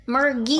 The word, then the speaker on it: Murgi: Murgi. – Gi. Nope. It's hard G. – Like, Murgi.